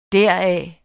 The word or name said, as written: deraf